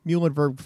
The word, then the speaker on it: Muhlenberg: Yes, for goodness sake, people. That's how you pronounce Muhlenberg.